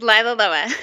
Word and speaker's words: Lila Loa: Yes, Lila Loa.